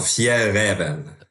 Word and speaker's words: Fjällräven: Genau, Fjellreven.